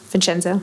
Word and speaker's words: Vincenzo: I feel like you really showed this when Vincenzo first, uh, maybe I'm not pronouncing it right, Vincenzo. Yes, How did you're good.